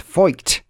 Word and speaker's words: feucht: It's pronounced feucht. She says, don't pronounce it unless you're a native German speaker there. Yes, and that's because obviously you could go horribly wrong. It's spelled F-E-U-C-H-T, but pronounced more or less feucht.